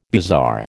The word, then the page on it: bizarre: American English